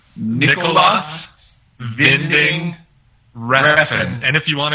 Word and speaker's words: Winding: Nicholas Winding... Refn. – Actually, I just have to step in here. It's actually pronounced Nicholas Vinding Refn.